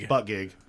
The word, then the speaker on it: Buttigieg: It looks like Butt Guy. – It's Butt Gig.